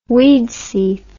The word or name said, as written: Widsith